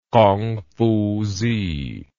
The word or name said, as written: Kongfuzi